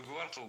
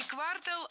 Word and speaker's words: квартал: Не квартал, а квартал. Причем такое ударение актуально при любом значении слова. И часть улицы, и часть года. Всегда в слове «квартал» ударяем второй слог.